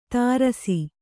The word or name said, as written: ತಾರಸಿ